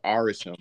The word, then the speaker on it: Arishem: The specific celestial in the movie that we see is Arishem. I think that's how it's pronounced, right? – Yeah.